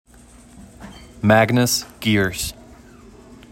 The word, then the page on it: Magnus Geers: MAG niss GEERS; rhymes with Pierce